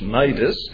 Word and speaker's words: Cnidus: it is Cnidus, the wind not permitting us to proceed, we sailed under the shelter of Crete off Salmone.